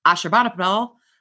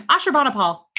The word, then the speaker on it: Ashurbanipal: And if you ever want to see what some of these parks looked like, a relief panel from the North Palace of Ashurbanipal, I said it right the first time, Ashurbanipal, go me,